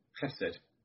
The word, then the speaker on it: chesed: These being, as English words, they're spelled ches and chesed, but I think they're pronounced chen and chesed.